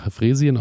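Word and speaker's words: Freesien: Heißt es Fräsien? Ich bin ja da ein absolut heißt es ja. – Fräsien ausgesprochen, Faulenlilien und Knollenbegonien sollten Ende, bis Ende Oktober ausgegraben werden.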